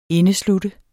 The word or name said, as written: indesluttet